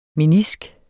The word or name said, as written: menisk